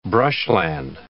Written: brushland

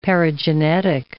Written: paragenetic

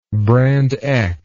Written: brand X

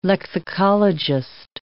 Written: lexicologist

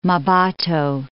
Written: Mmabatho

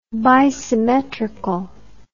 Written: bisymmetrical

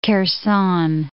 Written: Kherson

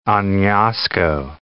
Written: Anasco

